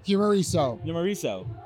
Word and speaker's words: Humoriso: He is the CEO of Humoriso. Did I say that right? – Humoriso. Italian. – Humoriso. – Yes, hey, that's right.